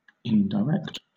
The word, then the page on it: indirect: British English